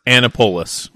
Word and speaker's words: Annapolis: I was like, yeah, it's pronounced Annapolis.